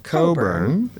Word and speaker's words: Cockburn: A-L-I-S-T-A-I-R. And the last name, which is pronounced Coburn, is a Scottish name, but it's spelled C-O-C-K-B-U-R-N with a silent C-K in the middle. – Yes. – So Alistair Coburn.